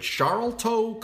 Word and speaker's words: Sharlto: which is spelled S-H-A-R-L-T-O. So if you've ever seen his name and you're like, is it Sharlto Copley? No, Sharlto, shawl, like you put on a shawl, Sharlto Copley.